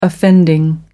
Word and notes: offending: American English

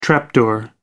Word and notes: trap door: British English

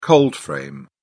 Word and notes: cold frame: British English

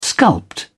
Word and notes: sculpt: British English

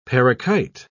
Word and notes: parakite: British English

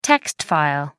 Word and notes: text file: American English

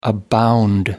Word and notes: abound: American English

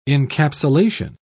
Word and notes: encapsulation: British English

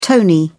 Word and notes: tony: British English

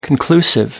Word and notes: conclusive: American English

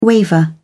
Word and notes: waver: British English

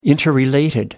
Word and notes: interrelated: British English

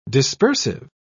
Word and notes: dispersive: British English

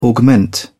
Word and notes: augment: British English

